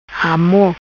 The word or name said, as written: Amor